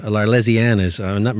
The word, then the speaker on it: L'Arlésienne: This is Bizet, L'Arlésienne. My French is not very good. I don't know if I'm pronouncing that even close. – Yeah, you got it right.